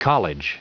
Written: college

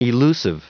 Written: elusive

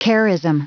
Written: charism

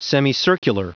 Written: semicircular

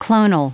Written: clonal

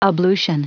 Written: ablution

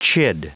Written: chid

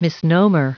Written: misnomer

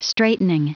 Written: straightening

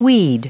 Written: weed

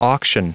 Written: auction

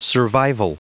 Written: survival

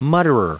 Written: mutterer

